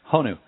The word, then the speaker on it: Honu: I know you're a busy guy involved in, among other things, being an author, father, movie and commercial acting, Hanu sandals. Am I saying that right? – Honu. – Honu.